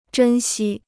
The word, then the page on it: 珍惜: zhēn xī